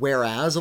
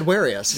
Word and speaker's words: whereas: I said Warius. Yeah, so whenever I said it out loud, I would say Warius, thinking I was pronouncing it correctly.